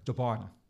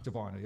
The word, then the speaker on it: DiBona: DiBona. – DiBona. – DiBona, yep.